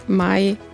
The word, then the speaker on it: Mai: Also mein Nachname Mai, wie der Monat Mai, ganz langweilig, anders als die meisten tatsächlich denken.